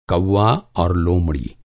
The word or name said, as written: कौआ